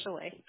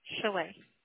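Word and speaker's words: Chaleighe: My name is Shalee. – Shelley? – Shalee. – How do you spell it, please? – It's C-H-A-L-E-I-G-H-E. – L-E-I-G-H-E.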